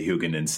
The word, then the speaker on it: Huguenin: uh, he got an assist by how do you say Huguenin's name? Did I say that right? That sounded pretty good. Huguenin. Huguenin sort of like Favre.